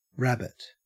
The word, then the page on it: rabbit: Australian English